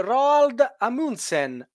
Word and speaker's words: Roald Amundsen: Oppure il norvegese Roald Amundsen a bordo della GH. Eh, questo l'ho detto bene, vero Miki? – Perfetto, direi.